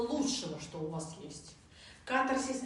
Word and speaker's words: катарсис: По-разному проговаривают катарсис, катарсис, но лучше катарсис. Чисто орфографически, фонетически, правильно, катарсис. Ударение на первую А.